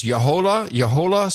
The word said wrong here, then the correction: La Jolla: Salt Corporation? J-O-L-L-A? La Jolla. La Jolla Salt Corporation. La Jolla.